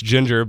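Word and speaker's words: Jinjer: I know it's pronounced Ginger, but it's spelled with two J's.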